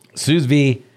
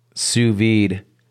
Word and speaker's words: sous vide: The second S is silent. – The D is silent. sous vide. I do.